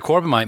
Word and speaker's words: Corbomite: My number one episode was, in fact, the Corbomite Maneuver. The surprise is that I can say it correctly.